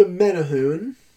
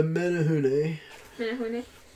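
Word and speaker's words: Menehune: No, I actually had to look up before the episode how to say this. This is the Menahune. The Menahune.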